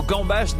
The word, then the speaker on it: gambas: Sachez en outre que le mot gambas n'existe pas au singulier. Oui, il y a un S final obligatoire qui se prononce comme pour nous rappeler qu'il faut les bouffer à gogo.